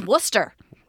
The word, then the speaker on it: Worcester: And I was like, you see the name of that town on that sign? That's Worcester. – Not Worcester. Worcester. – Not Worcester. None of those letters are pronounced. – Sorry, what is it really? – Worcester. W-O-R-C-E-S-T-E-R.